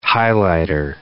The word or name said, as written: highlighter